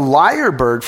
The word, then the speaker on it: lyrebird: And there's lots of contenders, but the lyrebird from South Australia has got to be one of them. And before I'm asked, it's spelled L-Y-R-E-B-I-R-D. Lyrebird, like the lyre, the harp and the lyre.